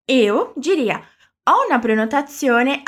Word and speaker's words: prenotazione: Aqui eu quero que você preste um pouquinho mais de atenção na pronúncia do Z em italiano. É igual quando a gente liga no delivery para pedir uma bella pizza. A pronúncia do Z na palavra prenotazione vai ser igualzinho a esse Z que falamos na palavra pizza ou pizzaria.